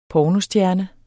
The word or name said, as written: pornostjerne